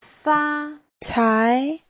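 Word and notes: facai: fa1cai2

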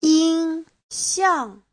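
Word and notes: yinxiang: yin1xiang4

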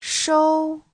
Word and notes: shou: shou1

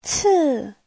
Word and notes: ci: ci4

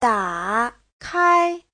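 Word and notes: dakai: da3kai1

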